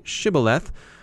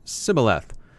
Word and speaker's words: Shibboleth: To identify spies from Ephraim, the Gileadites made suspects say Shibboleth, a word the Ephraimites inevitably pronounced Sibboleth.